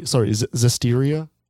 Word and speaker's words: Zestiria: Legendia, Abyss, of the Abyss, Tales of Basiria, Exilia, Zestinia. Oh, sorry, Zestiria.